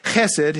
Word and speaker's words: chesed: That word steadfast, the word that is translated steadfast love is the Hebrew word chesed. And if you don't spit a little bit when you say it, you're not saying it right.